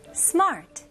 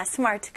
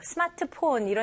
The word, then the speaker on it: smart: 근데 발음은 이름절로 하셔야 됩니다. 영어로 할 때는. 그래서 스마트 이렇게 하면 R 발음이 완전 생략됐잖아요. 여기서는 R 발음 해주셔야 돼요. 그래서 smart. 오케이? 여기서는 R 발음을 당기면서 힘을 주셔야 돼요. 이거는 마트 할 때도 mart. mart. 여기서는 smart. smart. Very good.